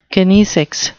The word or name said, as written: kinesics